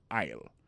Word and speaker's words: aisle: Aisle là, c'est A-I-S-L-E. Vous prononcez aisle.